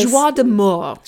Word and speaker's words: joie de mort: He's got a joie de mort. – Mm-hmm. Yes, indeed. Oh, boy. Don't know what happened there. And that's how you got to roll your R's for the French.